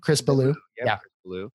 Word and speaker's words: Chris Ballew: Chris Ballew.